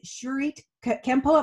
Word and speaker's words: Shuri Kempo: You are a fourth degree black belt in Japanese karate and a third degree black belt in Shuri Kempo. Am I saying that right? – Yes, and actually, since I guess I need to update that, I am a sixth degree black belt now.